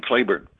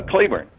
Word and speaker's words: Cleburne: And in Ireland, it's spelled C-L-E-B-U-R-N-E, and it's Claiborne, Texas, because a lot of his men after the war settled out there, and they named this little town where they were living Claiborne. But the Irish pronunciation is Claiborne, like it was C-L-A-Y. So most of the historians call it Claiborne, and it's still Claiborne, Texas.